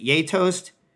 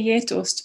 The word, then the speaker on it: geitost: Is it Geitost? How do you pronounce that? – I would say Geitost, but I'm sure that's a Swedification.